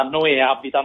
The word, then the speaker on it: Noè Abita: Allora, guarda, è molto interessante rispetto alla pronuncia, perché in realtà Noè Abita, no, che hai pronunciato benissimo, perché ovviamente i francesi mettono l'accento anche dove non c'è, è tipo un po' il burro, mettono accenti o... – esatto. No, nel senso che l'accento Abita il cognome Abita è un cognome italiano.